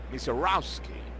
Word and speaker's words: Misiorowski: Maciorowski con W intermedia y con SK y latina al final. Se escribe Misiorowski. Le dicen en inglés Misiorowski. La pronunciación de la familia, que es la que nosotros respetamos siempre.